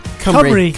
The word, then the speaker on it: Cymru: Kimru, is it you pronounce it, I think? – Cymru.